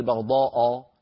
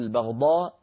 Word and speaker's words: البغضاء: ولا نفخم الهمزة ولا نقول البغضاء، نقول البغضاء بهمزة مرققة.